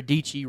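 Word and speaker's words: Dietschy: And she had one of the keyboard YouTubers make her a keyboard, and her catchphrase is Sarah Deechee rhymes with peachy, right?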